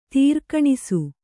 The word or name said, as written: ತಾರ್ಕಣಸು